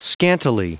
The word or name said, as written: scantily